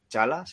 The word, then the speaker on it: T'Challa: Vale, pero en 2014 ya nos dijeron, en la misma de esto que presentaron a Chadwick Boseman como T'Challa, Chala, sí, ya lo digo bien.